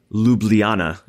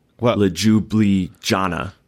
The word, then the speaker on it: Ljubljana: Last Friday, I published an article with Luka's Slovenian trainer, who is, I'm mentally building up, Jure, because the J is a Y sound. It's why Ljubljana is the capital, not Ljubljana, which it would be the very American way to say it if you pronounce the J's, but the J is a Y.